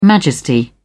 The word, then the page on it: majesty: British English